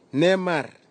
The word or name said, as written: nemar